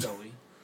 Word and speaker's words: Zoe: Zoe, however you say her name. – It's Zoe. – I'm pretty sure it's Zoe, yeah.